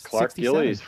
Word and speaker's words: Gillies: Yes, Clark Gillies from the legendary New York Islanders teams.